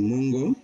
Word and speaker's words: Moengo: M O E N G O Moengo, and that's Samara Samora um, new album, and that's S A M O R A, right?